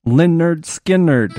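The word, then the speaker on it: Lynyrd Skynyrd: It's pronounced Leonard Skinnerd.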